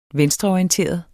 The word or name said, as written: venstreorienteret